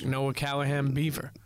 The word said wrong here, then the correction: Noah Callahan Bever: My guy. Like, or Bever, am I saying it right? – Noah Callahan Bever.